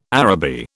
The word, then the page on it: Araby: British English